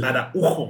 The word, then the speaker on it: Araujo: Es Araujo, güey, me caga que tenga el acento en la U.